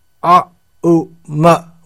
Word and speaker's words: aum: In other words, om is pronounced a, u, ma, when these three syllables are joined together, a, u, ma, then we get aum. Oh, it's written in English, A-U-M.